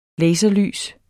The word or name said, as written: laserlys